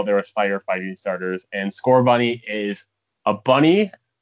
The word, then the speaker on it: Scorbunny: It's going to include three new starting Pokemon, which are going to be Scorbunny, Sobble, and Grookey. – Are we pronouncing those right? – Yes, you are so far. – Okay, Scorbunny, Sobble, and Grookey.